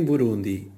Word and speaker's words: Burundi: a tónica em Burundi e afins é flutuante, já em rum, na sílaba rum, já na última sílaba, di.